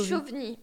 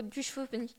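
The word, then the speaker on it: Duchovny: Est-ce que tu crois que le V, on le prononce? – Bah, c'est au choix, soit David Duchaugny, soit David Duchaugny. – Duchauvny. Mais moi, je pense que en langue française, toutes lettres se prononcent quand même, donc ce serait plutôt Duchauvny.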